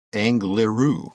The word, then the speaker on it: Angliru: the Angerlu. Angliru. I think I said that correctly.